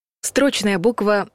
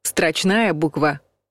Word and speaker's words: строчная: Строчная буква — неправильный вариант. Строчная буква — правильный вариант. Есть буква прописная или заглавная, большая, а есть строчная, маленькая.